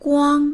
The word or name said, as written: guang1